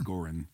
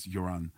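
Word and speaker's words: Göran: Everybody calls him Goran, but his the pronunciation's Joran.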